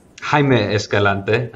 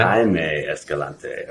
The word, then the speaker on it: Jaime Escalante: And, you know, your quote is sort of strikes the same vein here, and it's by Jaime Escalante. Did I pronounce that right? – Jaime Escalante. – Yeah. Jaime Escalante.